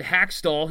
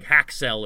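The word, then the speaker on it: Hakstol: Said Hackstall, whose name he kept mispronouncing. He was like calling him like Hacksell or something.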